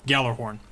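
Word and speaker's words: Gjallarhorn: So we're going to, for the purposes of this episode, I think we're mostly going to stick with Galahorn since that's what comes out of Bungie. That's how they say it over there, so let's say that over here.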